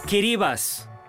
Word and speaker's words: Kiribati: Kiribati. É assim que deves pronunciar o nome das ilhas Kiribati. Sabes porquê? Porque é assim que o fazem os seus habitantes.